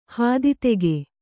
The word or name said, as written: ಹಾದಿದೆಗೆ